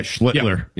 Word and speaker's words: Schlittler: I've seen probably Skeens pitch twice on TV, and I've seen, how you say his name, Schlittler? – Yeah, Schlittler. Yeah, the top I've seen him pitch once early in the year.